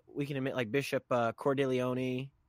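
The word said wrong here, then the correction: Cordileone: I don't know how to say his name. – Bishop Cordeleon.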